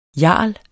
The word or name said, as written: jarl